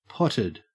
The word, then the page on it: potted: Australian English